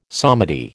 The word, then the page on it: psalmody: American English